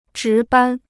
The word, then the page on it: 值班: zhí bān